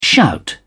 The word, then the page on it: shout: British English